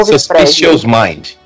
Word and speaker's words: Suspicious Mind: Mas ela chama Suspicious Mind. – É Suspicious Mind.